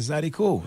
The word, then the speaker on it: Zydeco: Because when I got out there and people didn't even know how to pronounce the word Zydeco, they said, how do you say that? – Yeah. – I said, Zydeco.